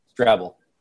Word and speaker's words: Strebel: Strabble. – Strabble? That's Strabble. Not what it looks like. Strabble. – Strabble. – Strabble. Strabble. Like Scrabble, but with a T? – Like treble clef with an S.